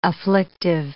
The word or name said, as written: afflictive